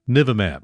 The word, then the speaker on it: Bamlanivimab: But if you want to pronounce it and sound erudite, start with the last three syllables. Nivimab. Emphasis on the n, kind of like the knights who say n, Nivimab.